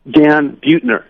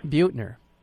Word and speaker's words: Buettner: Dan Buettner. – Buettner.